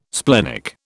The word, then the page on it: splenic: British English